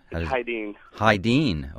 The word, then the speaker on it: Hydeen: Hydeen. I wouldn't get that. – Yeah. – On the bass. – I pronounced it wrong for a while. – Well, it looks like Hayden, but not the composer, Hyden.